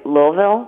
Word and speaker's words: Louisville: And although I struggle saying Louisville, I think the locals say Louisville.